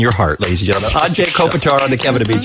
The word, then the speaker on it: Anže Kopitar: Anže Kopitar.